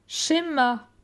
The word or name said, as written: Schema